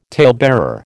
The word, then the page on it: talebearer: American English